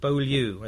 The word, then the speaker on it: Beaulieu: Beaulieu, as it's pronounced in America, is a well-known, one of the classic old Zinni.